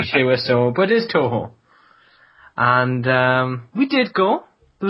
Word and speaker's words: Toho: Am I pronouncing that correctly, or is it... – Toho, that's right, yeah.